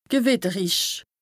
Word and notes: gewìtterich: Bas-Rhin; Strasbourg

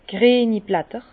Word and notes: d' grieni Blätter: Bas Rhin; Herrlisheim

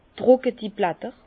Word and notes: d' drùcketi Blätter: Bas-Rhin; Herrlisheim

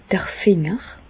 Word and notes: d'r Finger: Bas-Rhin; Reichshoffen

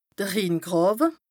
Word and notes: de Rhingràwe: Bas-Rhin

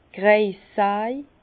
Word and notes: d' Kreissaj: Bas-Rhin; Herrlisheim

